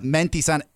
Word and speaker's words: Mentisan: So Menti Sun. – Spell it. – Menti Sun, MENTISAN. – Nice, okay. – Menti Sun.